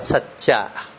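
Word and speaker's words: sacca: Ariya Satcha, chữ này đọc là Satcha.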